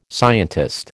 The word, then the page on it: scientist: American English